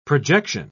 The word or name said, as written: projection